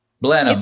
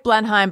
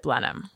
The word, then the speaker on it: Blenheim: Blenheim. It's spelled like Blenheim, but you pronounce it Blenheim.